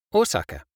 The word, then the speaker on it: Osaka: It's the same with Osaka.